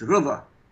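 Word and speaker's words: Reza: In Arabic, it's Rıza. Means Rıza, that's it, Rıza, yeah.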